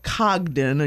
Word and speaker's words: Congdon: The Loft at Cogdon. Again, we're going to say that's how it's pronounced. C-O-N-G-D-O-N Yards. You can look it up.